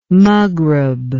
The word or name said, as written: maghreb